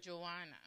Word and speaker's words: Joanna: Jonah. – That's how you pronounce it here. – Isn't that? Oh, Joanna? – Oh, okay. Because I was like, maybe that's American pronunciation. Because if you say Jonah, there, then it's like, I didn't see the extra. – Jonah, the wife of Chuza, that means like their gays. Okay, so Joanna, right? – Joanna. – Okay, that's it's the same pronunciation here. Okay.